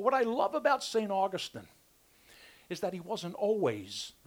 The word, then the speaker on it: Augustine: Sometimes they call him Augustine. It's actually pronounced Augustine. St. Augustine was a fourth century, so like the 300s, a fourth century theologian.